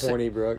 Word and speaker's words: Hornibrook: I say Hornibrook. – It's Hornibrook. – I don't say Hornibrook. – That's how you pronounce it.